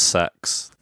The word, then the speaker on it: CeX: It's actually pronounced sex. – Is it really? – Apparently the corporate people said, apparently it's sex.